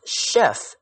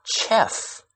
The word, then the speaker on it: chef: And why is this word pronounced chef and not chef? – Well, because it came from the French language. Chef in French means chief. So a chef is literally the chief of the kitchen.